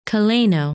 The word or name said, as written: Celaeno